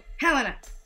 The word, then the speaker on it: Helena: Now we know how to say it. – Helena.